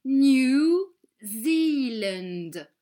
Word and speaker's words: New Zealand: In English, Nouvelle-Zélande se dit New Zealand et se prononce New Zealand en étirant le son I.